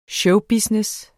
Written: showbusiness

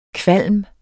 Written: kvalm

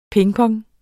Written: pingpong

